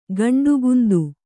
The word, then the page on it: ಗಂಡುಗುಂದು: Kannada